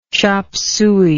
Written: chop suey

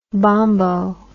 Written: bombo

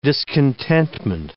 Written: discontentment

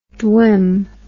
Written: dwim

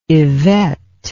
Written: yvette

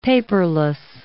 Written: paperless

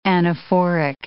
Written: anaphoric

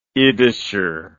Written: yiddisher

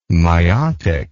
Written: myotic

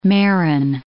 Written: Marin

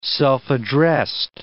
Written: self-addressed

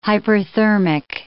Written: hyperthermic

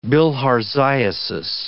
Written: bilharziasis

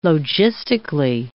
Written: logistically

